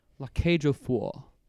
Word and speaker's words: La Cage aux Folles: La cage aux folles.